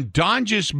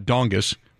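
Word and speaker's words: Donges: By the way, it's not Dongus. I'm glad you said Donjus. I didn't know how to pronounce it. I thought it was Dongus.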